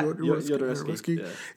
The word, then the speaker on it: Jodorowsky: So there's that, and then there was like the Jodorowsky. I don't know. Is that how you say it? – Yeah, Jodorowsky. Yeah, he had a version too that it got like never really made or whatever.